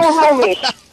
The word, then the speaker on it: Snohomish: Snohomish. Yeah. One of the few Indian names that pronounces exactly like it looks.